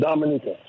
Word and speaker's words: Dominica: Right, but it's the natives, they pronounce it Dominica.